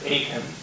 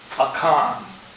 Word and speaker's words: Achan: Now, some people pronounce it Achan, but in the Hebrew it is pronounced Achan. A-C-H-A-N.